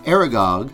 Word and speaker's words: Aragog: Chapter 15 that we're going to discuss today, called Aragog. Did I say that right? – Yes.